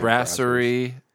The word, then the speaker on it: Brasserie: Yeah, Brasserie.